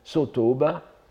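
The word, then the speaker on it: Sotōba: Sutongpo, euh, prononcé en, japonais Sotooba,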